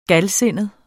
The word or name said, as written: galsindet